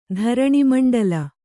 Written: ಧರಣೀಮಂಡಲ

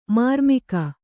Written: ಮರ್ಮಿಗ

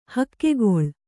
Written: ಹೆಗ್ಗುಳ್ಳ

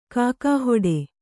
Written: ಘೋಕುಹೊಡೆ

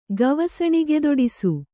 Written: ಗವಸಣಿಗೆದೊಡಿಸು

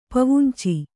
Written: ಪವುಂಚಿ

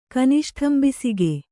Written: ಕನಿಷ್ಠಂಬಿಸಿಗೆ

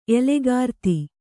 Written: ಎಲೆಗಾರ್ತಿ

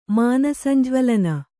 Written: ಮಾನಸಂಜ್ವಲನ

